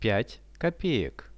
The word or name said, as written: 5 kopeek